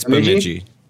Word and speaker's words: Bemidji: I'm terrible at pronunciation. – I think it's Bemidji. Yeah.